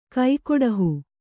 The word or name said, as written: ಕೈಕೊಡಹು